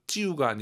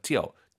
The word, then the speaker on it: 就: 여기서 찌우가 아니라 아이유 사이 오너서 찌어.